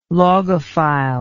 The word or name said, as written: logophile